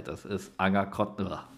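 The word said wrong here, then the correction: Anger-Crottendorf: Nee, ich kann es nicht mal aussprechen. Anger Kottendorf. Jetzt, ich habe es hinter mich gebracht.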